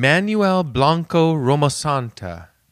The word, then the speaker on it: Manuel Blanco Romasanta: So this is a Spanish man named Manuel Blanco Romasanta. Am I allowed to say it like that? – Yeah.